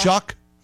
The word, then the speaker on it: Shough: Spelt S-H-O-U-G-H, pronounced yeah. Shuck. – Yeah, nothing like it should be.